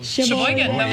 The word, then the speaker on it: Sheboygan: Sheboygan, Wisconsin.